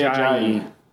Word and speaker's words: Ajayi: How do you say his name? – Jay Ajayi. – Okay, one of you say his name.